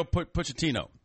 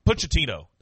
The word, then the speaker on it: Pochettino: Pacetti? Or what is Gosh. Pacetti or U.S. U.S. – Oh, Mario Pochettino. – Pochettino.